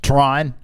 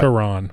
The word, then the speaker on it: Tron: You just made that two syllables. – Did I? What? – Tehran. – I didn't say Tehran.